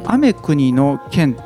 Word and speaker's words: 天国の剣: 雨国の剣っていうですね、うん、まあ、天国の剣と書いて雨国の剣と読むんですけども、